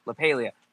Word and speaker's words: LaPaglia: So they're going to this home that's owned by this family called the Mullins, and Anthony LaPaglia, I can never say his name right, and I love him. And it's so frustrating. – LaPaglia.